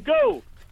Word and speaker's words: Goole: It's from Goole. – From Goole. Oh, my goodness. You've fulfilled the brief. – Yeah, we were looking for a one-syllable town and you've come up with it.